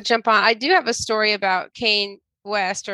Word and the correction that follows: Kanye: Are you talking about Kanye? – What did I say? – Kane? – I met Kanye, and he goes by Ye now.